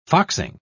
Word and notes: foxing: British English

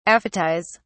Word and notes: aphetize: British English

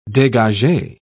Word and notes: degage: British English

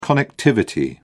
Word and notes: connectivity: British English

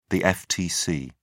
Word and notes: ftc: British English

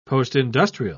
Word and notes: postindustrial: British English

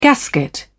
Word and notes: gasket: British English

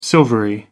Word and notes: silvery: American English

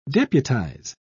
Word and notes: deputise: British English

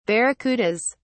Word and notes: barracudas: British English

